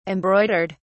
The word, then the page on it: embroidered: British English